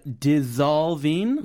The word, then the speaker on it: dissolving: We had dissolving. – Yeah, dissolving. 어, 발음 이거 연습해야 될 것 같아요. – 아, 약간 Z 발음.